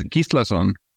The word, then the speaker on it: Gislason: So it's Gislason.